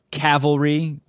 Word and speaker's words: Cavalry: Cavalry, which I keep saying Calvary, and Joe keeps correcting me, as he should.